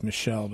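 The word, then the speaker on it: Michel: It's a German girl from Germany named Annalise Michael. Some people say it's Michelle, but it's spelled like Michael.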